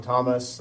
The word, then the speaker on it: Thomas: For the record, my name is Sean Thomas. That's Sean spelt with a W, last name T-H-O-M-A-S.